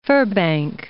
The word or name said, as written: Firbank